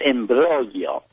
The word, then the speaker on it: imbroglio: The other night you used the word imbroglio. It's imbroglio, which means a great big confusing mess.